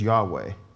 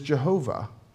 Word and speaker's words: Yahweh: So there's two primary ways in which that name does get pronounced. One of them, and the most common way, is to, in English anyway, is to pronounce it as Yahweh. That's an attempt to kind of pronounce those, and you've heard that before as God's name. There's another, which is Jehovah, which is, believe it or not, just another pronunciation of that same Hebrew name, which it does sound quite different.